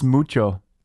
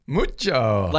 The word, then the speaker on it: Muccio: It's pronounced Muccio. – Muccio. – Like Mucho Grande? – Josh, mucho.